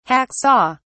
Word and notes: hack saw: British English

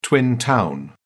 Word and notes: twin town: British English

